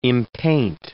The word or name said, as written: impaint